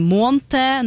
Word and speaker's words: måned: Men kan det her også ha noe med et annet spørsmål vi har fått inn, som handler om hvordan vi uttaler ordet måned? At noen sier månte, noen sier månt.